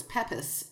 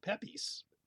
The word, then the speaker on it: Pepys: We have Samuel Pepys in there, which I always pronounced, of course, Pepys, like any other, you know, person. Yeah. – Right, it's P-E-P-Y-S. – Yeah. I heard about Samuel Pepys, which would obviously be spelled P-E-E-P-S, like the little, you know, soft Easter candy.